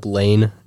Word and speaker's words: Layne: My name is spelled L-A-Y-N-E, I guess. If you look up Lane, L-A-Y-N-E, on Instagram, probably find me.